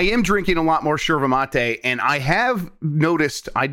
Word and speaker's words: yerba mate: Yerba Mate is how it's spelled, but they pronounce it Sherva Mate, and you know I want to be authentic.